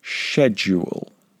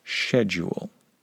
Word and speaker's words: schedule: The British, people from the United Kingdom, would pronounce this word schedule. Now, since I've been living in Spain, my accent, my pronunciation, has become a little more American. In the past, I would have pronounced this word schedule. But nowadays, I found that people find it easier to understand me when I say schedule.